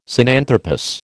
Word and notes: Sinanthropus: American English

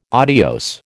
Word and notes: adios: American English

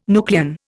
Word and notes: nucleon: Indian

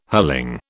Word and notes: hurling: British English

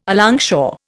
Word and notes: alongshore: Indian

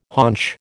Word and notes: haunch: American English

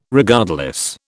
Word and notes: Regardless: British English

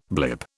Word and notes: blip: British English

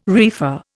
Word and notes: reefer: Indian

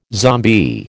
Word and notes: zombie: American English

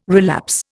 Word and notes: relapse: Indian

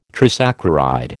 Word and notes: trisaccharide: American English

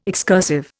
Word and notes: excursive: Indian

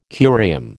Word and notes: curium: American English

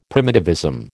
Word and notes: primitivism: American English

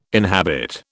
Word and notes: inhabit: British English

